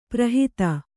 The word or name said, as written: ಪರಹಿತ